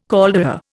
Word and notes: caldera: Indian

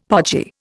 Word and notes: podgy: Indian

